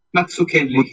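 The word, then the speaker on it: Mazzucchelli: En David Mutsakeli, no sé cómo se pronuncia. Matsukeli. Matsukeli. Matsukeli.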